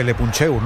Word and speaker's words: Le Puncheur: Le leemos ahí en, no sé cómo se dice, Le Puncheur, no sé cómo se dice eso, Sergio. – No, lo has dicho bien, lo has dicho bien.